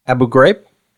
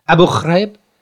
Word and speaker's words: Abu Ghraib: Abu Ghraib. Am I saying this right? – I think so. – Okay. Abu Ghraib.